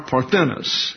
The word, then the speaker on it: parthenos: For example, parthenos, or parthenos is the correct pronunciation.